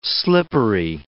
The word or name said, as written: slippery